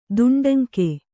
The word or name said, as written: ದುಂಡಂಕಿ